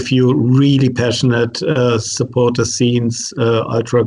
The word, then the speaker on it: ultra: but let me pronounce it German, ultra.